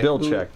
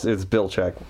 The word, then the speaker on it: Belichick: Belichick, actually. – He's the... Oh, Bella what? – It's complicated, but it's Belichick. – Belichick? – Yeah. – Why do I want to put an extra syllable in? – Because everybody does.